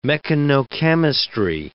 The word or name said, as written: mechanochemistry